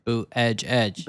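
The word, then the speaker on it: Buttigieg: Boot, edge, edge.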